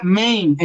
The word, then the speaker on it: Maine: Dice, dice Quique Gitano, que pronuncié mal, que, que se pronuncia Maine en Estados Unidos, no como yo lo leí.